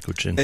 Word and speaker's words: Cochin: Is that the right pronunciation? – Yes. – Now, where is that, Cochin? – It's in the southern part of India.